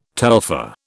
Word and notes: telpher: British English

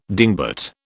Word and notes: dingbat: British English

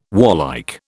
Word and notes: warlike: British English

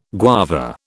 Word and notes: guava: British English

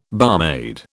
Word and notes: barmaid: British English

